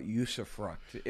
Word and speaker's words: usufruct: Talk about usufruct.